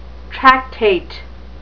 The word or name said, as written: tractate